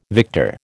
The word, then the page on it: victor: American English